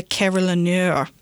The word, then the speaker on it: carillonneur: The person who plays a carillon, and I might mess up this pronunciation, is a carillonneur, like a restaurateur.